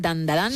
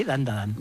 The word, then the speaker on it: Dandadan: Tenemos Dandadan, creo que lo he dicho bien, Dandadan. – Sí, Dandadan.